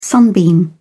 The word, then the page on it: sunbeam: British English